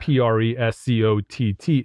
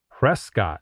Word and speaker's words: Prescott: Prescott is not Prescott, but it's Prescott. I was told like biscuit.